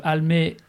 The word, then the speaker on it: Almé: Almé, comme ça se prononce, avec un « e », c'est important.